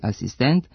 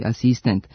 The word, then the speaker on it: asistent: Zbog toga se oblici sa kratkosilaznim akcentima na posljednjem slogu, dirigent i asistent, akcentuju kao dirigent i asistent sa kratkouzlaznim akcentima na pretposlednjem slogu.